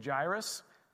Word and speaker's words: Jairus: So there's Jairus, there's Jairus, and then there's Jairus. So just for Erie Christian Fellowship Church, I'm just saying we like to say Jairus. This is uh, Liz's pronunciation of this word, of this name, because that's what we call our little man.